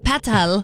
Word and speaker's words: Petal: I love Ariana Grande's new album, and she pronounces it Petal, kind of like Moira Rose.